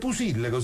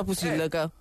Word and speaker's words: Pusilleco: Pusilleco si scrive. – Pusilleco. E si pronuncia Pusilleco. – Esatto. – Ok, allora l'ha detto bene.